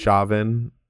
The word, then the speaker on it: Chauvin: Chauvin, Chauvin, however you pronounce his name. Chauvin, I think? Chauvin. – Yeah. Yeah. Sounds right to me as a result of Officer Derek Chauvin's actions on May 25th, 2020.